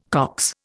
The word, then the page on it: Cox: Indian